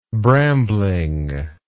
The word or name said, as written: brambling